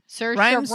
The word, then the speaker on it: Saoirse: Saoirse Ronan. – Rhymes with inertia.